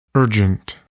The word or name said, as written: urgent